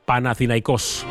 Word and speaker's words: Panathinaikos: El Fenerbahce ha caído 7-7-8-1 frente a Panathinaikos. La forma de pronunciarlo. Panathinaikos.